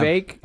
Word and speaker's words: Vivek: Vivek? Oh, I think it rhymes with cake.